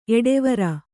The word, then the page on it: ಎಡೆವರ್: kannada